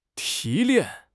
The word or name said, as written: 提炼